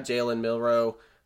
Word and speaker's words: Jalen Milroe: Obviously, they have Jalen Milroe.